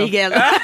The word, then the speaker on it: Miguel: Et mon prof, il est trop cool, il s'appelle Miguel, il est super. Et il s'appelle Miguel? – Oui. – Pourquoi? Il est espagnol? – Oui, il est espagnol. – Alors ça, c'est prononcé Miguel. – Miguel.